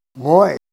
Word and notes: goy: Ninilchik Russian

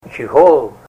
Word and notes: chihol: Ninilchik Russian

